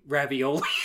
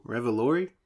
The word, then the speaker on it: Revolori: I forgot that Tony Ravioli.